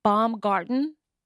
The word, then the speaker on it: Baumgarten: Did I say your name right? Baumgarten? – Yep.